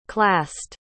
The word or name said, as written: classed